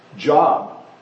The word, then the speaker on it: Job: And so when the computer was reading back to me what I wrote, it said job. So it was pronouncing it job. It looks like job. It's the same word in English, but for the name, we pronounce it Job. It's pronounced Job.